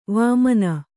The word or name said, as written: ವಾಮನೆ